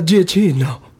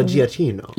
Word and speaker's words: Giacchino: Uh, it, it, seems to be Italian, so G- Giacchino, G- uh, You have to say it like an Italian. You have to say uh, uh, uh Michael uh, uh, Giacchino.